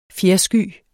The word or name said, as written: fjersky